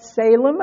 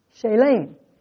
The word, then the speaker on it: Salem: Melchizedek, who is a type of Christ, the king of Salem, I guess we would all say, but you know what it says in Strong's Concordance? It's pronounced Shalem, which is from the root word shalom.